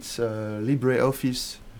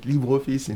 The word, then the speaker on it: LibreOffice: Office Suite, LibreOffice, if you pronounce it the English way, but LibreOffice in French.